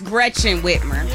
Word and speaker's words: Gretchen Whitmer: I'll let it slide because we got two tickets and I'm a hook up Sarah regardless, but it's Gretchen Whitmer.